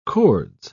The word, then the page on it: cords: British English